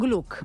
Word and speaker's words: Gluck: Pues no, pero es la respuesta al chico de la Vía Cluck o la respuesta al ragazzo de la Vía Cluck. ¿Lo he dicho bien? – Gluck. – Gluck. – ¿Gluck con G? – No, con C. – Se dice Gluck.